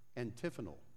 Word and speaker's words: antiphonal: He says it's antiphonal, or antiphonal, that's the way you pronounce it.